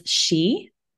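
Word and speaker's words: sìth: In Gaelic, they're known as she, which is spelled SITH. And there's many places in Scotland that are named after this pronunciation.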